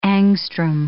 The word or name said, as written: angstrom